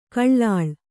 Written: ಕಳ್ಳಾಳ್

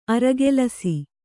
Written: ಆರುಕೆಲಸ

